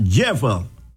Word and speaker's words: Jephthah: And I'm talking about Jephthah. Capital J-E-P-H-T-H-A-H. Jephthah. That's the way it's pronounced.